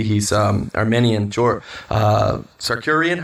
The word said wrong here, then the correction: Arman Tsarukyan: How do you say it? Arman Tsarukyan. Tsarukyan. Tsarukyan.